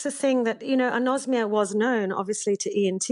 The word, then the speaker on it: anosmia: Now, apparently this is called anosmia, the loss of smell. Is that right, how you say it? – That's exactly right.